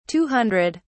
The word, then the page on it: two hundred: British English